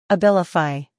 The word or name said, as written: Abilify